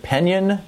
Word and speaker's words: Penn Yan: And to get started here, uh, Ron in is it Penn Yan, New York? Am I saying that right, Ron? Uh, yes, you are.